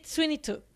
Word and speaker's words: Sweeney Todd: Yo elegí Sweeney Todd.